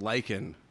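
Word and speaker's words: Leichen: Well, I'm saying that lichen is corpse in German. – Well, let me see. – But it's spelled different. But pronounce the same.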